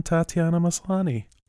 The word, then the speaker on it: Tatiana Maslany: This is how I know that there's more than one Tatiana Maslany.